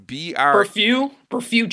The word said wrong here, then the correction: Brebeuf: Jesuit. I'm gonna go with that. – Brefeu. It's Brebeuf.